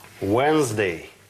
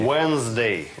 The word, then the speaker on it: Wednesday: Wednesday. – Wednesday. – Wednesday. – А вот пишется так. – То есть D не читается? – Нет.